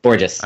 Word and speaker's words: Borges: Borges, yeah. – I always question how to say it because I look at it and I think, is this French? – He does not go with the bourgeois.